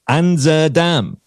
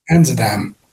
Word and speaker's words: Anzadam: Asdenam, another one, and I'm sure I'm after butchering that pronunciation, as Anzadam. – Anzadam.